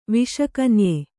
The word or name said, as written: ವಿಷಕನ್ಯೆ